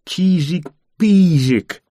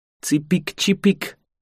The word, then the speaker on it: чижик-пижик: Не только из-за двух «и», которые на самом деле произносятся «и», а еще потому, что первые полгода я его называл «цыпик-чипик», что я считаю гораздо более подходящее название для птички, ведь птички тоже не умеют говорить искоса. Когда мне наконец-то сказали, как правильнее, я начал говорить «чисик, писик».